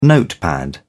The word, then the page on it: notepad: British English